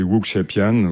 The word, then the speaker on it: wuxiapian: Donc, je vais peut-être mal le prononcer, le Wuxiapian. – Oui, Wuxiapian.